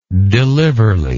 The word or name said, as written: deliverly